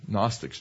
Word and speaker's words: Gnostics: There's a group of people called the Gnostics, spelled with a G, Gnostics, G-N, who said that Jesus was never really a human.